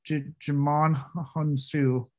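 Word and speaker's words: Djimon Hounsou: It's like, either get a better agent or do whatever you need to, but stop doing these shitty fucking supporting characters, Mr. Jimon Hunsu. I really hope I said that right. – Yeah, I think you did.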